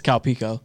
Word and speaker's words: Calpico: It's Calpico.